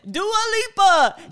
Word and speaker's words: Dua Lipa: Sarah Beth says she's loves the Dua Lipa shirt. Is that how you say it? – Hello. Okay. Listen, Dua Lipa.